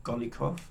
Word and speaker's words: Golikov: Golikov, am I pronouncing that reasonably well? – Yes.